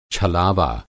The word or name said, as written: छलावा